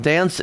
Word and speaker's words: DNCE: It's called Cake by the Ocean by, I guess it's pronounced Dance, D-N-C-E.